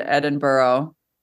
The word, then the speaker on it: Edinburgh: So we went over and we kind of centered around Edinburgh, which I've learned is the way to pronounce it.